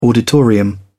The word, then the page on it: auditorium: British English